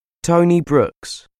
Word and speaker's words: Tony Brooks: Tony Brooks.